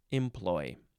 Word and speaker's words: employ: You can emphasize the first or the second syllable. Number one, A, the synonyms are use and purpose. Use and purpose, employ.